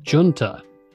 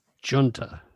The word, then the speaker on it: Junta: Now, last week when we selected this, I pronounced it Junta, which if we were talking about just that word by itself used in other contexts, that would be correct. But right. Apparently, this one is pronounced Junta or Junta.